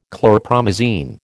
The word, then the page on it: chlorpromazine: American English